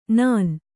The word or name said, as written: ನಾನ್